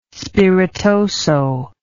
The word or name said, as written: spiritoso